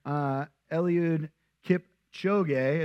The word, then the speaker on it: Eliud Kipchoge: But basically, it was this Kenyan runner, Eliud Kipchoge, is how you say his name.